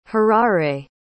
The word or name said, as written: harare